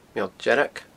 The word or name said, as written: Meall Dearg